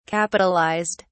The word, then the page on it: capitalised: British English